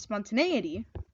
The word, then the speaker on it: spontaneity: No, it's an A, like a horse, spontaneity. If it was spelled with a U, it'd be pronounced spontaneity.